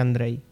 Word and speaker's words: Andrzej: Creo, sin saber polaco, que se pronuncia Andrzej. – Bueno, pues... – La J como si fuera una I.